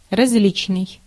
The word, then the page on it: различный: /rɐzˈlʲit͡ɕnɨj/